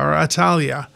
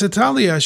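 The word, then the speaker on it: Athaliah: Well, there was a woman named, I would pronounce the name Atalia, who was the daughter of, the voice might just said like the Italian airline.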